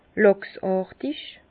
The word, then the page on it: làchsàrtig: Bas-Rhin; Herrlisheim